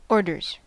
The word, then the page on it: orders: American English